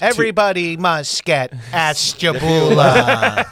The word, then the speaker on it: Ashtabula: Ashtabula, Ohio? – Yeah, Ashtabula.